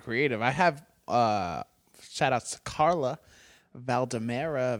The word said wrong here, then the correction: Valderrama: Valde, I don't know how to say it. Valdemera. Valderrama. Valderrama. Yeah.